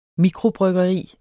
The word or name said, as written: mikrobryggeri